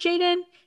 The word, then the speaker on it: jxdn: But yeah, no, this is spelled JXDN. And I actually like looked it up. I was like, is it? I mean, I'm assuming it's pronounced Jaden. It says it is, according to good old Google.